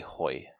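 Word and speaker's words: Ke Huy Quan: And number 1, I have to give to Ke-Hu Kwan. I'm sorry, I'm butchering this man's name. Who played Wayman. – Ke-Hoi. – Ke-Hoi, thank you.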